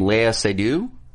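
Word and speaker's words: Léa Seydoux: And please help me as I butcher her name, Lea Seydoux.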